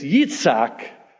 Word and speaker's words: Yitzhak: Now, we pronounce the name in English, Isaac. But in Hebrew, it's Yitzhak.